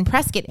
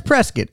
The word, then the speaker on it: Prescott: She says Prescott. I think locals say Prescott. Mm. It looks like Prescott.